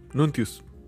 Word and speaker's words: Nuntius: Que también sigan al zorro en Calamum Nuntium, Nuntius. Siempre, lo digo mal. ¿Cómo es? – Nuntius.